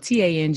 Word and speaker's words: Tanjareen: If you know, my name is not spelled like the fruit, it's spelled Tanjareen, just like right there, Tanjareen.